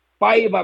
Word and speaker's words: Paiva: el León ya tiene técnico y es este señor Paiva, si lo sé pronunciar de manera correcta. – Perfecto, sí, perfecto.